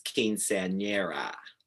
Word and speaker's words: quinceañera: I'm actually going next week for my niece's quinceañera.